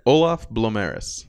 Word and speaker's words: Olaf Blomerus: Olaf Blomerus.